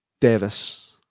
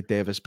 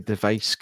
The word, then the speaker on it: Device: I need to tell myself to say Devis because I keep wanting to say device because that's how it's spelt. And it's also how Derek Acora said it on Most Haunted, but I'm just saying. Right. I'm getting the name device. I'm like, oh, it's Devis, mate.